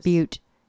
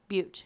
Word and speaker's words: Butte: That's pronounced Butte. Butte. Yes. If it's B U T T E, it's Butte. Yes, Butte.